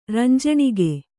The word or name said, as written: ರಂಜಣಿಗೆ